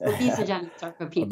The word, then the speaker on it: obesogenic sarcopenia: I hope I'm pronouncing that correctly. – Yes, obesogenic sarcopenia.